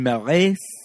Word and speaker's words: Marais: Jean Cocteau, starring Jean Marais, probably the S is silent, Josette Day.